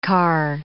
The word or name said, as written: carr